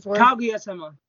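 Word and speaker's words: Kaguya-sama (かぐや様): Kaguya-sama, I can't pronounce the first part of it. – Kaguya-sama.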